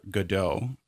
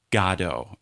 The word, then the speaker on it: Godot: It's not Waiting for Godot. It's the proper pronunciation is Godot.